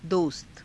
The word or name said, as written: dosta